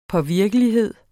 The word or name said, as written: påvirkelighed